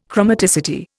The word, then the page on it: chromaticity: Indian